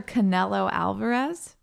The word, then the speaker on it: Canelo Alvarez: This puncher guy, Mr. Canelo Alvarez, did I say that right? – Yeah.